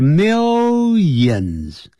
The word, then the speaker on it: millions: 不要念成 millions，不是 lions，就 millions，没有眼睛的那就这样念法一样， millions of animals，